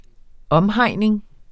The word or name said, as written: omhegning